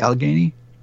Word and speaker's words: Allegheny: Is it, did I pronounce that correctly, Allegheny? – You did.